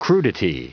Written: crudity

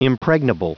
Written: impregnable

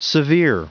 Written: severe

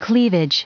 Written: cleavage

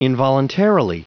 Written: involuntarily

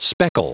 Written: speckle

